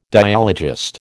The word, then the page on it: dialogist: American English